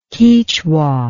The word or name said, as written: Quichua